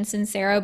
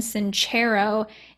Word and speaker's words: Sincero: I pronounced her name wrong. I thought it was Jen Sincero, but it's Jen Sincero, and it's You Are a Badass.